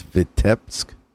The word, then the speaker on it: Vitebsk: You grew up in the former Soviet Union in, I'm going to butcher this, Vitebsk. – You did good.